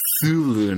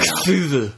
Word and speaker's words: Cthulhu: I've heard it's pronounced Cthulhu now. – Cthulhu. – A little more S to it.